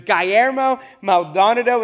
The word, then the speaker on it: Guillermo Maldonado: Actually, last time I used this quote in a message a couple years ago, I pronounced it Guillermo. And my wife afterwards was like, that's not how you pronounce the name. So it's Guillermo Maldonado.